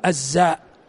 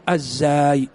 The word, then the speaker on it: الزاي: الخز يعني بالخاء والزاي، ترى بعض الناس إذا جاء ينطق الزاء يقول الزاء ويكتب الزاء وهمزة، هذا غلط. الصحيح الزاي بمعجمتين، يعني نقطة على الخاء من أجل الخاء ونقطة من أجل الزاي، ولكن هذا ضبط غير مشهور.